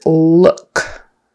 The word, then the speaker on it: look: The word look. That has a horseshoe U in the middle, It's not an O, it's not a long U, it's not a short U, it's somewhere in the middle, it's like someone punched you in the stomach, So look, book, cook, cooking, those all have that horseshoe U sound, Look, not Luke, not Lok, not luck, that'd be a different word.